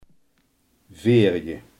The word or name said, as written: vierde